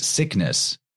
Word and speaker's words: Cygnus: Cygnus, or sometimes actually spelled or pronounced sickness, because G and C sounds are interchangeable.